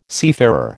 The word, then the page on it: seafarer: American English